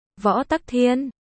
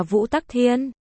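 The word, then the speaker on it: võ Tắc Thiên (武则天): Học võ Tắc Thiên, xưng đế, võ Tắc Thiên, cũng được đọc là Vũ Tắc Thiên, thường gọi võ hậu hoặc thiên hậu, là một phi tần ở hậu cung của đường Thái Tông Lý Thế Dân.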